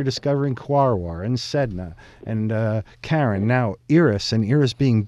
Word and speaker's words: Charon: It's spelled Charon. People would say Charon, but it's really Charon, pronounced Charon.